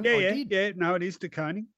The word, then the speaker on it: De Koning: I think his tweet said something like it's De Koning's world, we're just living in it. Look, probably the most critical duel of the match, really, in some ways, De Koning on and it is De Koning.